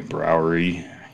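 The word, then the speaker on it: Brouwerij: It's B-R-O-U-W-E-R-I-J. Keys. – Oh, that's a Belgian word for brewery. Browery. Yeah.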